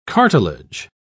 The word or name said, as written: cartilage